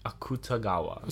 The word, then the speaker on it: Akutagawa: This is based on two works by Ryunosuke Ryunosuke Akutagawa! – Yep, that's my first try. – That pronunciation was just me being enthusiastic. – Those are two very difficult words, let alone a name. – Akutagawa.